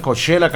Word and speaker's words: Coachella: Ah, caray, sí se pronuncia igual, Coachella, California. Especialmente para Lugo Conde, que no picha ni un agua de cebada.